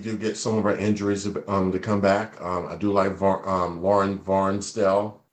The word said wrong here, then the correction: Van Arsdale: I think it's Van Arsdale.